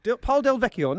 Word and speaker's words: Paul Del Vecchio: how do you pronounce this? Can you, our pronunciation geezer? Come over to the microphone. – Paul Smith. – No, he makes jeans. – Paul Del Vecchio.